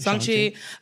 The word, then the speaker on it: Shang-Chi: We skipped The Incredible Hulk, Iron Man 3, Thor Dark World, Guardians of the Galaxy, Ant-Man and the Wasp, Black Widow, Shang-Chi or Song-Chi? I don't know how to pronounce it. – Song-Chi.